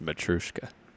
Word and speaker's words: Matryoshka: It seemed like there were too many syllables in there. – Matryoshka? – Yeah, I think it's just Matryoshka.